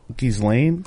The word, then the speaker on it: Ghislaine: G-U-I-S. – G-H-I-S-L-A-N-E. – No, I? – G-H-I-S-L-A. Ghislaine? – Yeah. There's I think it's L-A-I-N-E. – Is it L-A-I-N-E? – Promise.